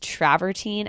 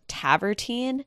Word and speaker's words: travertine: Because she kept referring to travertine as tavertine. And I kind of felt bad that no one was correcting her, but maybe I'm just pronouncing it wrong. It's definitely spelled like travertine. But again, she was dropping the R and just calling it tavertine.